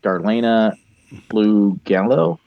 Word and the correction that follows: Darlene Flugel: Darlene Flugel. – Flugel.